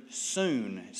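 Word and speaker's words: soon (σύν): The unsearchable riches of Christ in this passage are introduced to us by the use of three compound words, all beginning with the Greek word soon, spelled S-U-N, pronounced S-O-O-N.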